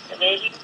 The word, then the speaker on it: Trevelin: ¿Cómo se dice? ¿Trevelin o Trevelin? – Trevelin, que es el pueblo del molino.